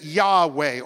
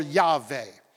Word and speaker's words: Yahweh: But the Jews added Hebrew vowel sounds and they said Yahweh or Yahweh.